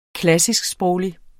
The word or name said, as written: klassisksproglig